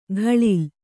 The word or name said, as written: ಖಳಿಲ್